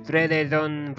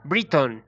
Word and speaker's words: Brereton: Breveton, Breveton, Bredeton, Briton. Así se pronuncia realmente.